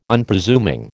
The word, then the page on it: Unpresuming: American English